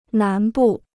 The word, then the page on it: 南部: nán bù